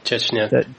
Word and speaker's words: Chechnya: Chechnya. – Chechnya.